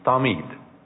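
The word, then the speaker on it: tamid (תמיד): If you want to spell it, it's T-A-M-I-D with a soft T, tamid.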